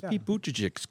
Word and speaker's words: Buttigieg: Give us Pete Buttigieg's coming to being story. Did I say his name right? Pete Buttigieg? – Yeah, sure.